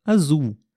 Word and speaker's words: azul: Outro exemplo bem simples é a cor azul. Azul. Azul também tem a letra L no final, porém nós pronunciamos como se tivessem duas letras U's na palavra.